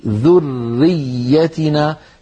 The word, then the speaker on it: ذريتنا: ومن ذريتنا، انظروا إلى كلمة ذريتنا، فيها راء مشددة وفيها ياء مشددة، راء مشددة وياء مشددة. ما معنى مشددة؟ يعني الحرف مضاعف، لابد أن ننطق راءين وياءين، لا يصح أن يقول القائل ومن ذريتنا ذريتنا هكذا يخطفها ويختلس الحرف الأول المدغم، لابد من بيانه هكذا ومن ذريتنا، فيكون النطق براءين وياءين، إلا أن كل من الراء والياء مشددة بمعنى يرتفع المخرج عن الحرفين ارتفاعة واحدة.